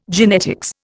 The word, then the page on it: genetics: Indian